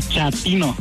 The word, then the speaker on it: chatino: Chapino. – ¿Chapino? – No, chatino. – Es como chato, ¿verdad? Chatino.